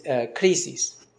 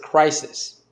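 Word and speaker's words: krisis (κρίσις): So after this, then the judgment, and the word judgment is krisis. And that's the word for judgment, krisis. It's, if you read it in English, you would pronounce it crisis.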